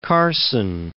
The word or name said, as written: Carson